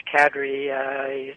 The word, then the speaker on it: Kadri: Well, first of all, your pronunciation is correct. It's Kadri.